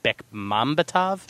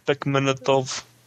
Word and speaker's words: Bekmambetov: Both of which are just superb movies, and we're going to see a lot more out of Tamar Bekhachlov, in the future. I can't pronounce his name. I'm sorry. It's just Bekmambetov, I think, or something. Tamar Bekmambetov. – Tamar Bekmambetov.